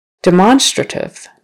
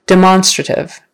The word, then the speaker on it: demonstrative: Now it's second syllable again. And we say it a little bit differently. You know, demonstrate is more like demonstrate. But demonstrative, now we all of a sudden pronounce that O like it's supposed to be. Well, that's judgmental. We pronounce the O kind of more like an O.